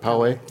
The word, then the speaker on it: Poway: Am I pronouncing Poway. – That correct? Poway, thank you.